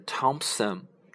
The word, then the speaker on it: Thompson: 比如说，我们有一个人名，嗯，是 Thomas，t h o m a s，Thomas，托马斯，这里面的那 h 呢就是不发音的。还有一个类似的名字是 Thompson，t Thompson 啊，那这里它是拼写是 T H O M P S O N，不是 Thompson，而是 Thompson 啊。